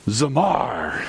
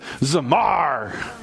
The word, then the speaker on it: Zamar: Zamar. Everyone say that. Sounds like fun. No, say it like I said it. Zamar. Oh, there you go. You got to do the Gretel thing in your voice.